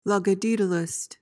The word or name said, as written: logodaedalist